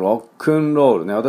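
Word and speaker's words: rock'n'roll: ロックンロール、ロックンロール、ロック and ロールなんですけども、ロック and とは言いません。ロックンロールと、もうアポストロフィーの N という感じですね。ロックンロールというふうに発音します。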